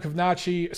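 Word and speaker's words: Kownacki: Kanachi. So other than the fact that I consistently butcher his name, you know, I like Adam Kovnacki.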